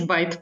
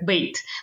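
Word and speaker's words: bait: Но это оказалось именно как бейт. Но из-за того, что она пишется B-A-I-T, а, а, мы уже в русском языке произносим как байт.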